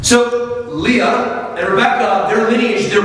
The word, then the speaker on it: Leah: Leah, how do you say it in English? Leah? – Leah.